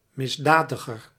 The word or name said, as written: misdadiger